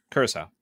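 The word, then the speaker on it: Curaçao: It's 1 ounce of Absolut vodka, Southern Comfort, and blue curacao? I don't know how you say it. – Curacao. – Thank you.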